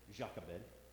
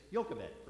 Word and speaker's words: Jochebed: If you just want to use English, you just say Jochebed. If you want to be more Hebrew inclined, the J sound is a Y sound.